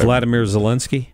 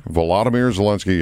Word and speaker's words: Vladimir Zelensky: Vladimir Zelensky. – Vladimir Zelensky, yeah.